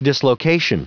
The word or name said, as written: dislocation